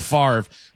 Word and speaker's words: Favre: Brett Favre is F-A-V-R-E. It's Favre.